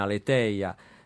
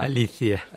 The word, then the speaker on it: Aletheia: e poi c'è chi la pronuncia la latina, Aletheia, addirittura c'è la pronuncia in greco moderno che poco fa mi suggeriva. – Alizia.